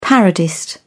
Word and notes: parodist: British English

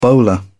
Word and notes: bowler: British English

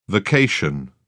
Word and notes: vacation: British English

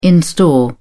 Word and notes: in store: British English

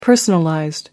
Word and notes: personalized: American English

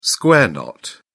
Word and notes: square knot: British English

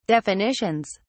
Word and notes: definitions: British English

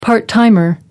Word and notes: part-timer: American English

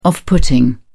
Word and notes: off putting: British English

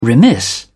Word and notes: remiss: British English

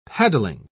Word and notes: paddling: British English

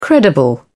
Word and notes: credible: British English

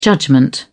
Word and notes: judgment: British English

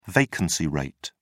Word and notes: vacancy rate: British English

